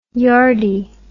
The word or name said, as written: yardie